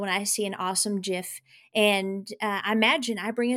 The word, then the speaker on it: GIF: Welcome to today's episode, and I want to talk about creating GIFs. Now, there is great controversy on how you pronounce GIF, which is spelled G-I-F. I believe it is with a J sound.